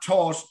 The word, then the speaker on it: thought: Yeah, hold that taught, T-A-U-G-H-T, how most Irish people pronounce T-H-O-U-G-H-T, at 9-1.